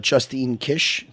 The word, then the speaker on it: Justine Kish: Nina, I was watching your last fight just now on the train over, your fight versus Justine Kish.